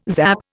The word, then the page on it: zap: Indian